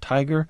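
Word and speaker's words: TIGHAR: He's director of the International Group for Historic Aircraft Recovery, pronounced TIGER.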